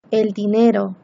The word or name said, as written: el dinero